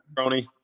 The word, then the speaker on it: Petroni: How do you spell that? – Petroni. That's Petroni. Correct.